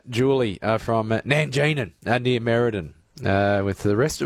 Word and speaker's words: Nangeenan: And good on you and your brother, the Nanganeen Town Hall. – So, no, it's not Nanganeen, it's Nanjing, and it's like N-A-N-G-E-E-N-A-N.